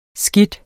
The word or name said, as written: skide